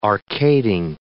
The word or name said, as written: arcading